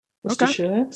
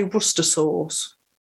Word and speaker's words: Worcestershire: It's spelled Worcestershire. That's how it looks. – Uh-huh. This has been a topic of hot debate amongst the podcasters here. I say it like that. I say Worcestershire, but there was hot debate. Let's put it that way. – Some people actually say Worcester sauce.